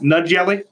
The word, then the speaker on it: Najee: Did you say Jelly Harris? – Yeah. – Yeah, it's pronounced Nud Jelly, but I call him Jelly. – Oh, Najee Harris. – Yeah. – That's what I said, Jelly.